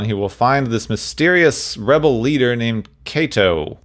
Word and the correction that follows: Quato: Quato. – Quato. – I keep pronouncing it Kato. It's Quato.